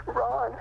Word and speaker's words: Raune: Okay, and your last name? – Ron. – How do you spell that? R-O-N? – R-A-U-N-E.